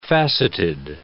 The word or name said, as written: facetted